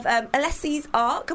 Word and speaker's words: Alessi Ark: We have Alessi Ark.